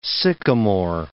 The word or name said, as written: sycamore